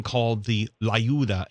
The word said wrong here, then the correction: Tlayuda: Tlayuda. – So I'm saying it wrong. So the T is not silent. – No, it's not. It's not per se silent, but yeah, it's, the T is very pronounced.